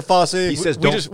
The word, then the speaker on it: Fauci: The top tweet here is Dr. Fauci, how do you say it? Anyway. – Dr. Fauci.